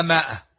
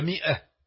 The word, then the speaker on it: مئة: إن يكن منكم مئة في المصحف العثماني الميم وراها ألف ومجعولة على رأسه دائرة لأنه لا ينطق، ولذلك تجدون العامة يقولون المئة مائة، ما هي مائة، لكن مئة في إملاء العربي تزاد وراها ألف، أما هي مئة لكن هذه الألف لا تنطق.